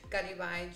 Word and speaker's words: Garibay: So Rachel with the E out, and then Garibay, Garibay.